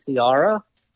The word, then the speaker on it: Ciara: Yeah, so it's actually Ciara. – Okay. – But, you know, it's spelled like Sierra with a C.